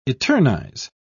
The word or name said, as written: eternize